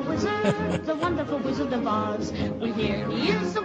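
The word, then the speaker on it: Os: But well, pronounced Oz with a Z.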